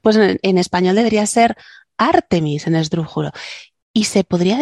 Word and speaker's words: Artemis: sino que está leyendo una palabra inglesa y la lee como se pronunciaría en español. – Mm. – Pero amiguitos, Artemis, por favor.